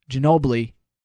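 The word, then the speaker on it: Ginóbili: How did I say that? Ginobili. – Ginobili.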